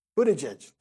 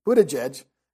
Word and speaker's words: Buttigieg: How do you pronounce his name? Buttigieg. Thank you. Buttigieg from Indiana.